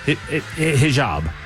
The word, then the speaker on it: hijab: We're going to call it a hijab. Hijab. Hijab. Hijab. Is that Ken? – Yes. – As the you're the official Matt Murphy Show pronouncer. – Yes, sir.